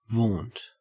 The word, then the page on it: vaunt: Australian English